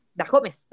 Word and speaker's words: Dajomes: Daisy Dajones, ¿no? Dajomes, Dajomes.